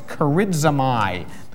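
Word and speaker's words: charizomai (χαρίζομαι): There is charismao, the Greek word to give. I actually pronounced that wrong. It's charizomai, the Greek word to give.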